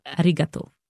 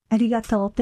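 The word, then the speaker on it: Arigato (ありがとう): Arigato. Yeah. – Arigato.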